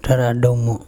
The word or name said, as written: TharaDumo